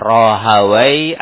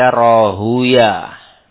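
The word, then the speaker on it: Rahawayh: Ada yang membaca Rahaway, ada yang membaca Rahuya. Nah, biasanya kalau ahli bahasa membacanya Rahaway, ahli hadis membacanya Rahuya. Bisa dibaca Rahaway, bisa dibaca Rahuya.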